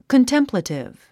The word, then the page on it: contemplative: kəntémplətiv; コンテンプラティブ